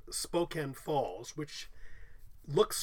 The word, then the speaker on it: Spokane: Most people have heard of this at least, but maybe aren't pronouncing it correctly. I think I know. It's spelled Spokane. I believe it's Spokane. – Yes. – Very good.